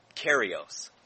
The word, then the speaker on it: kairos: The Greek word here that's translated time is kairos, and it's spelled K-A-I-R-O-S.